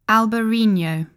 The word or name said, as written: Albariño